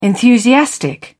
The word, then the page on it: enthusiastic: British English